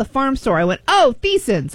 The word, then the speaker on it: Theisen's: It's spelled T-H-E... I-S-E-N-S. – Thiessen's. – And I looked at it and I was always like, I'm pretty sure that's Tyson's. – It's a lot of letters for Tyson's. I say it's Thiessen's.